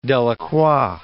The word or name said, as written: Delacroix